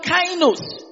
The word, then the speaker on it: kainos: The word new is from the Greek word kainos. Kainos is spelled K-A-I-N-O-S.